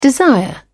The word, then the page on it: desire: British English